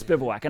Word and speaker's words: Bivouac: How do you guys pronounce it? How would you pronounce this? Does Bivouac. – Anybody know? Yeah, good. Okay, it's Bivouac.